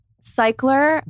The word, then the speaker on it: Cyklar: And I just want to know it's pronounced C-Y-K-L-A-R. – Oh, thank you. So cycler,